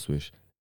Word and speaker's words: SWSH: I think it's pronounced Swish. SWSH.